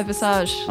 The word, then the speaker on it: Visage: Yeah, Visage.